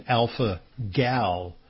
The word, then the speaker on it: alpha-gal: So if you're an alpha guy, you might think that the counterpart is an alpha gal. You might be mistaken, because alpha gal, and it is spelled just like a gal, G-A-L, alpha-gal, is a syndrome.